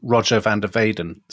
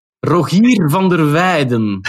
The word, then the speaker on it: Rogier van der Weyden: His successor on the Burgundian court is someone like Rogier van der Weyden. And you could also do a podcast on him. – I think we would be too tempted to call him Roger van der Weyden so that people in England knew who he was.